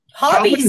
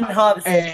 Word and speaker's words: Hobbes: Hobbies. You call them hobbies? – No. – Calvin and Hobbes.